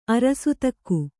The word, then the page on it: ಅರಸತೂಕ: Kannada; arasatūka